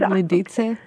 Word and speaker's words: Lidice: So we pronounce it as Lidice. – Lidice. – Mm-hmm. – Lidice, because there is the ts sound.